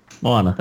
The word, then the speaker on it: Moana: I hope I'm pronouncing that right. – Moana? – Moana, there you go.